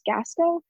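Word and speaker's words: Gascho: There's this veterinarian, Abby, I think her last name is pronounced Gasco, Landis.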